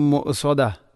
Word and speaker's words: مؤصدة: ഇവിടെ പലപ്പോഴും നാറും മുഅസദ മുഅസദ എന്നാണ് വായിക്കാറുള്ളത്. ഹംസക്ക് സുക്കൂൻ വന്നാൽ അതിനെ കലകല ചെയ്യാൻ പാടില്ല. മുഅസദ മുഅസദ അങ്ങനെ തന്നെ വായിക്കണം. – അലൈഹിം നാറും മുഅസദ.